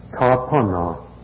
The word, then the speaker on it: Tarpana: The Sanskrit is Tarpana, spelled as it is pronounced.